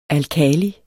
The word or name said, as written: alkali